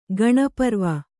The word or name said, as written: ಗಣಪರ್ವ